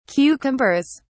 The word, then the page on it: cucumbers: British English